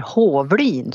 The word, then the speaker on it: Hovlin: Ja, mitt namn är Katti Hovlin, eller Hovlin som det egentligen ska uttalas för det är ju jamska.